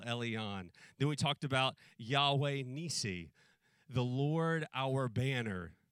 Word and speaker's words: Yahweh: And in Scripture, it's spelled with these 4 letters, YHWH, but we pronounce it Yahweh.